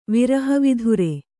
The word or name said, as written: ವಿರಹವಿಧುರ